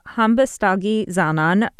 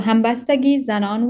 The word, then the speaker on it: Hambastagi: It's called Hambastagi Zanon. Shabnam, am I pronouncing that correctly? – Yes. Yeah.